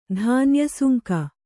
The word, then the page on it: ಧಾನ್ಯಸುಂಕ: kannada